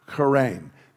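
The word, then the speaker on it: charein (χαίρειν): We would spell it like C-H-A-R-E-I-N, charein, and it just meant greetings.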